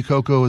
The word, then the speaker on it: Cuoco: That line from Kaylee Cuoco, is how we're pronouncing her name, I didn't know that.